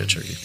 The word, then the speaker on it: Cherokee: The thing about Native Americans, they knew where their territories was, like the Cherokee, is that how you pronounce it? – Them, yeah, Cherokee.